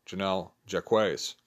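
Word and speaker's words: Jennell Jaquays: I've called her Jacques, seeing the video. I learned, much like Nate Treme, I learned how to pronounce her name, Janelle Jacques.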